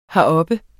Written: heroppe

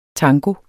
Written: tango

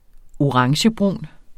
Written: orangebrun

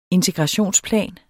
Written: integrationsplan

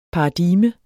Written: paradigme

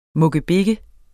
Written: mukkebik

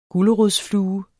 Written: gulerodsflue

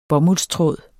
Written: bomuldstråd

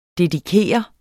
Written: dedikere

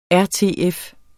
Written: RTF

